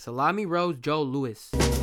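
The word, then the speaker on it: Salami Rose Joe Louis: Next track on here we have is Magazine featuring Salami Rose Joey Lewis. No, I didn't say that right. Salami Rose Joe Lewis.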